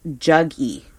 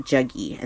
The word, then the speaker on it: Jaggi: His name again is, it's spelled J-A-G-G-I. Juggy is how you pronounce that. Juggy. And then the last name is V-A-S-U-D-E-V.